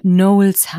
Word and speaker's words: Knowles: Nicht nur, weil sie es wissen soll, was damals alles passiert ist, sondern auch, weil sie ja mit Nachname Knowles heißt. Und das wird natürlich gleich ausgesprochen, wie Knows und Knowles.